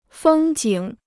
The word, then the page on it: 风景: fēng jǐng